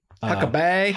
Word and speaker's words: Huckabay: It's spelled B-A-Y. – Huckabay. – Huckabay. Some people say it that way, but I'm like, it's Huckabee. I ain't saying Huckabay.